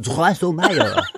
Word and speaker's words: Drosselmeyer: It's actually pronounced Drosselmeyer.